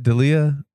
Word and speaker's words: D'Elia: That's how you say it. D'Elia.